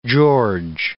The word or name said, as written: George